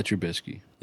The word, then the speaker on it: Trubisky: Trubisky.